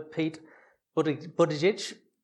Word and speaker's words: Buttigieg: Pete Buttigieg.